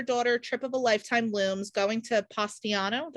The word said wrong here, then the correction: Positano: Did I say that right? – Positano. – Positano, yeah. Positano.